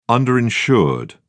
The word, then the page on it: underinsured: British English